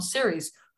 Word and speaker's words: Serres: So today, my guest is Tom Searies, who is the co-founder and managing partner of Warburg Searies Investments.